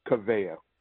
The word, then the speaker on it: Kaveya: So you rolled out the new, and am I pronouncing it correctly? Is it Kaveya or Kaveya? – Kaveya. – Kaveya, yes.